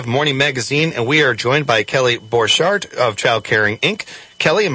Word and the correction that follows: Borchardt: You're really close. Borchardt. – Borchardt. Thank you, Borchardt. I was mispronouncing letters that shouldn't be mispronounced there. Borchardt.